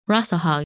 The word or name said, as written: Rasalhague